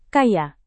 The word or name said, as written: kaya